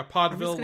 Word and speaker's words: Podville: Yeah, Podville, like vaudeville.